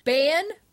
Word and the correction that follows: Baen: Publishing, B-A-E-N. They actually publish science fiction works, and they're still taking submission. Bane? – Yeah. – Am I saying it wrong?